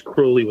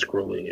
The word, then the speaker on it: Crowley: His Crowley's poem, The Winged Beetle, is his own poem that rhymes Crowley with holy. So I think it's Crowley.